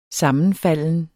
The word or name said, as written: sammenfalden